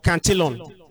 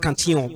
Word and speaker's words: Cantillon: So, this what I've just explained now is called, there's a term for it, it's called effect or cantillon. It's a French name. It's pronounced cantillon, but English we say cantillon. So it's called the cantillon effect. It explains how, you can look it up, C-A-N-T-I-L-L-O-N, cantillon effect.